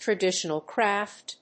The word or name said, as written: traditional craft